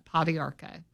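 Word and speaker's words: pau d'arco: It is P as in pan, A-U-D-A-R-C-O. That's how you spell that, Podiarco.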